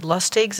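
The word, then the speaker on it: Lustig: Dr. Robert Lustigs, is that how you pronounce his last name? – Yes.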